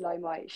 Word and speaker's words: Limai: Like, shout out to I don't know how to pronounce her name properly, but is it L Limai or like Limau? Yeah, it is Limai.